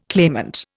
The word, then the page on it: claimant: Indian